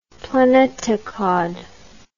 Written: planetokhod

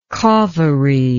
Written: kaveri

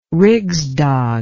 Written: Rigsdag

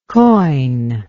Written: coign

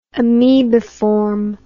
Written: amoebiform

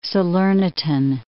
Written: Salernitan

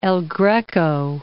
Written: Greco, El

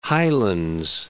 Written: Highlands

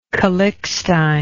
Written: calixtine

